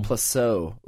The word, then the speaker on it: Plasso: Plaso? – Is it pronounced Plaso? Plaso? Plasso? – Um, Plasso? Plasso. – Plasso. Ooh, I like that. It's French.